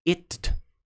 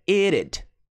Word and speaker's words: it'd: Então, a contração de it, apóstrofo D, você não fala it, tá? É it'd, it'd, it'd better, it'd better be ready when I get there, it'd, it'd, it'd, it'd, it'd better be ready when I get there.